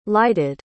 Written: lighted

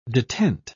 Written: detent

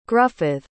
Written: gruffydd

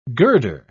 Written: girder

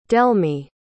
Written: delmy

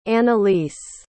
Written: analise

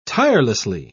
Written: tirelessly

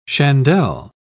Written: chandelle

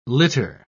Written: litter

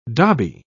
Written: dobby

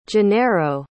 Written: gennaro

